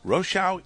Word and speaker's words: Rochow: And yes, Rochow is the way to pronounce that name, even though you've accepted Rochow for a long time.